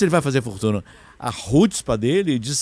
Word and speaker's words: chutzpah: A palavra é chutzpah. Se escreve C-H-U-T-Z-P-A-H.